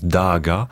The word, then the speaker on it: Dagda: Enfin, normalement, ça se prononce Daaga, et qui est le bon dieu.